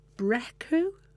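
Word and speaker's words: Brecqhou: They're also owners of Shop Direct, and they live together in a mansion on the English Channel island of Brecu. I think that's how you pronounce it. Yeah, it's a strange spelling.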